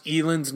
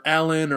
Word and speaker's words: Elin: Another thing that really pissed me off was no one can pronounce Elin's name right. People were calling her Ellen or Aline. Rachel, you could tell, fucked her name up. That really pissed me off. It's Elin.